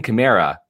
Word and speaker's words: Kamara: Alvin Kamara, and you said it correctly, they're hiding Jameis to a degree by becoming really balanced on offense.